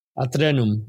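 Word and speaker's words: Aeternum: Let's see if I pronounce this in Spanish. It's Atrenum. Okay. Rosa in Atrenum.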